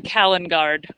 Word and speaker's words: Kaliningrad: Kaliningrad. – Yes, I'm going to Kaliningrad. – Kaliningrad, I mispronounced it.